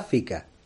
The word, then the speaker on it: fika: ¿Y qué es la FICA? Pues se escribe F-I-K-A. – Fica de toda la vida. Y ¿eh? se pronuncia Fica.